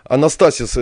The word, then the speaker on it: Анастасис: Анастасис – это правильное ударение, да? – Да, это правильное ударение.